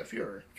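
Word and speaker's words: Führer: Fuhrer? How do I say that? – Yeah, Fuhrer. – Fuhrer? Okay, fantastic. So, with the attack of the dead men, I specifically came into this cold.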